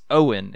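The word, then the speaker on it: Eoin: Yeah, it's the brothers Michael Sandison, or Mike, and Marcus Owen is how you pronounce it.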